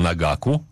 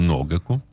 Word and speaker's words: ногаку: Мне сказали э, знающие люди, что ударение на на О. Так. А, хотя я э, настойчиво всегда говорил Нагаку, оказывается Ногаку.